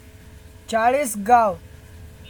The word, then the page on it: चाळीसगाव: Chalisgaon